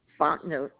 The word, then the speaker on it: Fontenot: Fontenot, F-O-N-T-E-N-O-T.